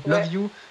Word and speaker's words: LOVU: Love You parce que c'est L O V U en un mot donc c'est le troisième titre je crois de ton premier EP yes.